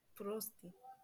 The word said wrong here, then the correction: Прости: Oh, I pronounced it wrong. Прости.